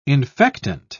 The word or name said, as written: infectant